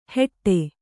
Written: ಹೆಟ್ಟೆ

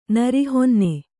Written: ನರಿಹೊನ್ನೆ

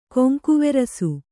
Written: ಕೊಂಕುವೆರಸು

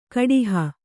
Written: ಕಡಹು

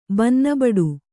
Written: ಬನ್ನಬಡು

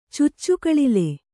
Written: ಚುಚ್ಚುಕಳಿಲೆ

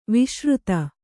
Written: ವಿಸೃತ

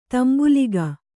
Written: ತಾಂಬೂಲಿಗ

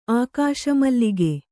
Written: ಆಕಾಶಮಲ್ಲಿಗೆ

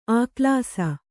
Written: ಆಕ್ಲಾಸ

